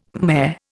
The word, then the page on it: Khmer: Indian